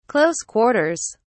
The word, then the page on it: close quarters: British English